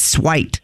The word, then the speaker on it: suite: This is suite or suit. – It's a different suite. It's a different kind of suite, spelled a different way. Suite. S-U-I-T-E, not S-W-E-E-T.